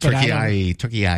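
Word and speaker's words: Türkiye: It's pronounced slightly different. – Turkey I.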